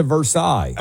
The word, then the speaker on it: Versailles: It's like Versailles being pronounced Versailles in Kentucky.